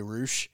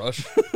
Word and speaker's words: Rush: Also, it's so hard for me to remember to say Roosh mm-hmm. because it's spelled Rush. – And not Rush.